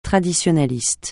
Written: traditionaliste

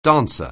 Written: dancer

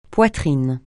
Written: poitrine

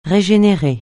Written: régénérer

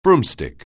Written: broomstick

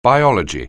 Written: biology

